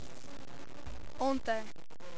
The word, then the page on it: ontem: portugal